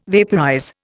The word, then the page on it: vaporize: Indian